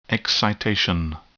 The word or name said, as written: excitation